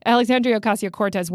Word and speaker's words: Alexandria Ocasio-Cortez: So Alexandria Ocasio-Cortez went on Twitter to defend...